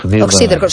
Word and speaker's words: Oxíderco: O-X-I-D-E-R-C-O. – Exacto, y con acento en la I. – Oxíderco, sí, sí, es esdrújula.